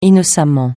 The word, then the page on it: innocemment: francais